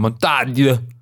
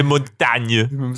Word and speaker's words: Montaigne: Das war aber, ähm, soweit ich weiß, die richtige Aussprache, Montaigne. Montaigne, Michel de Montagne. Äh, das klingt eher wie so Arnold Schwarzenegger.